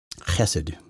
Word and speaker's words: Chesed: Chesed, which is spelled cheesehead, C-H-E-S-E-D.